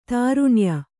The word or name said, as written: ದುರ್ಣಯ